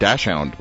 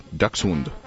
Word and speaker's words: Dachshund: now this is how you pronounce this, folks, Dachshund. Everyone calls it Dachshund, but it's a Dachshund.